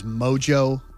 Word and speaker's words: mojo: I had mispronounced, I don't know why, but I didn't pronounce it as mojo. I said like moho or moha. – Is it moho or mojo? – No, it's mojo. – I think it's mojo. – It's definitely mojo.